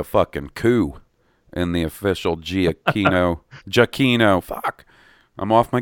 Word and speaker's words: Giacchino: So that gives me lots of hope for my good buddy, Michael Giacchino. Oh, my God. I just said it wrong. Giacchino.